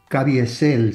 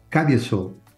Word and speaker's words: Caviezel: Se dice así, pero es Jim Caviezel, si lo decimos como dominicano. Uh-huh. Caviezel, así lo dicen los gringos.